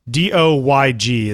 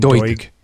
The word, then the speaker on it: Doig: There's a pronunciation thing on Wikipedia. I think it's Doig. Doyg is how they say to pronounce it. Does that mean Doig? – Okay. – Doig. It's kind of like a dog with an oi in the middle.